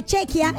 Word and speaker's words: Chequia: Y para los que están diciendo, ay no, así no se dice, pues mis lindos, sí se dice. Sí se linde, porque sí se dice, porque Chequia está muy bien dicho.